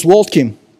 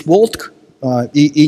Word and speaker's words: Уолтки: В русском языке почему-то пишут Брюс Уолтк и не произносится. Возможно, я не прав, просто я привык вот с семинарских лет произносить Брюс Уолтки именно с «и» на конце.